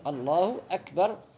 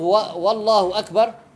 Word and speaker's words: Allahu akbar: Allahu akbar. C'est pas valable de dire Wallahu Akbar, ni Allahu Akbar. À la place, c'est les deux, c'est Hamza. Allahu Akbar. Les deux commencent par A. Allahu Akbar.